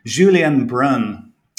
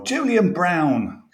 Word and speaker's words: Julien Brun: So I'm going with Julien Brun, or Julian Brown, to give his English pronunciation.